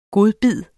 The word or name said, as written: GODBIDDERNES